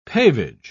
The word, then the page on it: pavage: British English